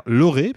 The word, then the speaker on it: Lorre: qui s'appelle Peter Lorre, parce que ça se prononce comme ça à la germanique, puisqu'il est allemand,